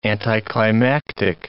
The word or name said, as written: anticlimactic